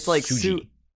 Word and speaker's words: Tsuji: Yeah, I assume it's pronounced Tsuji. – Yeah, no, it's like Tsuji.